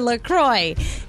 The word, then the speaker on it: La Croix: And they even say on their website, it's definitely pronounced La Croix. And if you ever get confused about it, just remember it rhymes with enjoy. So enjoy your La Croix.